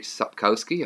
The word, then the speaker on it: Sapkowski: Witcher series, a beloved video game series adapted by CD Projekt Red from the series of novels by Andrzej Sapkowski, I hope I said that right.